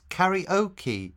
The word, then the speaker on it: karaoke: We pronounce it karaoke in English with the emphasis on the O.